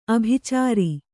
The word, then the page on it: ಆಭಿಚಾರ: ābhicāra